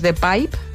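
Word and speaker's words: De Pijp: A mí un barrio que me gusta mucho también es The Pipe, que se escribe P-I-J-P.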